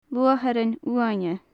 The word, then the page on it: luatharan uaine: gaelic